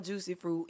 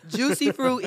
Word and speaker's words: Jucee Froot: My Instagram is I am Jucee Froot, and it's spelled we know how to spell that part. – Yeah. – Jucee Froot is spelled JUCEEFROOT. Because I've had people call me Jucee Foot.